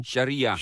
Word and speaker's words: sharia: Sharira driehoek of zo. – Sharia.